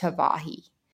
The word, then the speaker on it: Hawaii: And the W is still pronounced as a V. They do not have a V in their alphabet. So their W makes a V sound. So Hawaii is actually pronounced Hawaii.